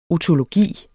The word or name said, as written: otologi